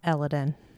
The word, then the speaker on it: Elodin: I liked Elodin, but I'm pretty sure according to P. Roth, it's Elodin.